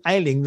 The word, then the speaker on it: Ayling: We've got Jonathan Ayling, the Chief Executive of the Free Speech Union.